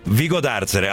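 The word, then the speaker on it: Vigodarzere: Francesco Vezzaro, sindaco di, forse sbaglio l'accento, Vigo d'Arzere. Allora, lo stavo prendendo giusto, siamo in provincia di Padova, buongiorno e benvenuto.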